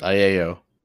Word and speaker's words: Aiello: Danny Aiello. How do you say it? – Aiello.